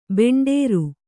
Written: ಭಂಡಾರಿ

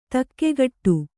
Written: ದಿಗ್ಗೇಡಿ

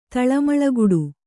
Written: ತಳಮಳಗುಡು

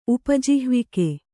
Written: ಉಪಜಿಹ್ವಿಕೆ

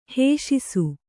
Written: ಹೇಷಿಸು

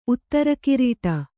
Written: ಉತ್ತರಕಿರೀಟ

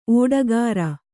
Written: ಓಟಗಾರ

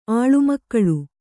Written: ಆಳುಮಕ್ಕಳು

